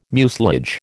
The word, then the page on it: mucilage: American English